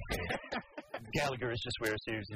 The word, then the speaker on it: Galaga: Yeah, Galaga.